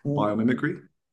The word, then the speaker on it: Biomimicry: Now, in your website, it says that Unstuck is grounded in biomimetrics. Did I pronounce that correctly? – Biomimicry.